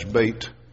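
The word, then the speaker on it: Bet: And then at the beginning of verse 9, you know, we'd anglicize and say that Beth. Actually, the pronunciation for that is Beit.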